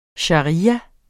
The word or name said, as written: sharia